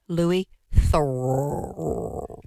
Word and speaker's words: Louis Theroux: Louis Theroux.